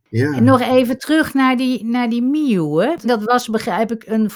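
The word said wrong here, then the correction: Myōe: Ik zou wel even willen vermelden dat, dat zijn naam wordt uitgesproken als Mieu, Myoe.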